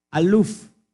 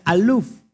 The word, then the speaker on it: aluf: Para decir mil en hebreo se dice aluf, que es exactamente como se escribe Aleph. Exactamente como se escribe Aleph, que es para uno, en hebreo es igual, pero suena diferente, con fonética diferente aluf.